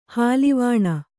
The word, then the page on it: ಹಾಲುವಾಣ: hāluvāṇa; Kannada